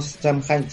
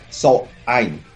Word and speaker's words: Samhain: Eh, bueno, es que viene de un origen que se le conoce realmente, este, le dicen Samhain, pero no Samhain, se pronuncia Sohain. – Sohain. – So, Sohain, exactamente, esa es el la, verdadera pronunciación de, de la fiesta. Se escribe Samhain, pero es Sohain.